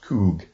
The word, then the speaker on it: chuwh: Coug. C-H-U-W-H. Pronounced Coug.